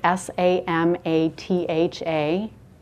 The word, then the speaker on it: samatha: Um, I don't think it's from the root soma, so the question is can we spell it? It's spelled S A M A T H A, so samatha.